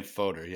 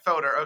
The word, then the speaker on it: foeder: in a wooden Foeder. I guess that's how we're pronouncing it. Is that how we decided? – I think we're saying Foeder. Yeah. – Foeder. Okay, great.